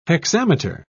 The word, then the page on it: hexameter: British English